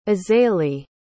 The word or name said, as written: azalee